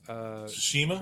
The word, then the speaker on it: Tsushima: So I did see Ghost of Shumi. It's not Shumi, but I can't pronounce it. – Tsushima? – Ghost of Tsushima.